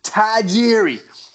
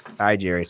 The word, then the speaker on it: Tajiri: And then Taz points out Jamie Noble's pronunciation of Tajiri, which is, can you hit me with it? – Tajiri. – Hi, Jerry. So really, he turns T-A into Ty.